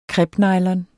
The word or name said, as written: crepenylon